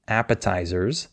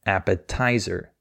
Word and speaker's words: appetizer: and she stressed the wrong syllable. She said appetizer, and the way she said it was very hard to understand for the native speaker because he was expecting her to say appetizer. That's the correct way to say it.